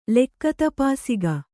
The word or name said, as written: ಲೆಕ್ಕತಪಾಸಿಗ